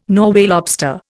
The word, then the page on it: Norway lobster: Indian